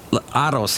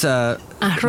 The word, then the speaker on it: arroz: They pronounce it TH. It's like they have a lisp. – Okay, like let's take a word. – Arroz. – Arroz. That's arroz.